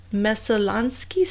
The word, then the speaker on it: Messalonskee: I mean, it was like a year later, in October of 2012, that police trawled the Messalonskee Stream. Is that how you say it? – That's a stream in Maine, yes. – Okay, all right. – So good pronunciation, by the way.